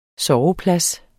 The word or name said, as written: SOVEPLADS